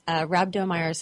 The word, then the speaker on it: Rhabdomyosarcoma: Rhabdomyosarcoma.